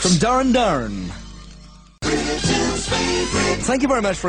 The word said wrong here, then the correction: Duran Duran: I said Darren Darren and Planet Earth at number 26, when of course it should be, as everybody knows, Duran Duran.